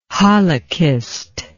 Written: halachist

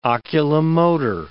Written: oculomotor

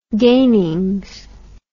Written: gainings

